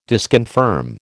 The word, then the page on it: disconfirm: American English